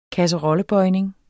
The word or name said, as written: kasserollebøjning